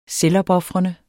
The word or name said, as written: selvopofrende